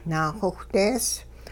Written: naaxowhdees

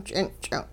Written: ch'inch'ing

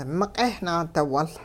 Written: miq'eh naadiwul

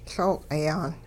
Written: Looq'i-yaan

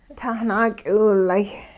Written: tahnaak'iwilay'

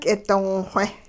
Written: k'idongxwe

